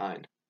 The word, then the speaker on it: Ein: I always see it written down, and I'm like, Ian. Ian.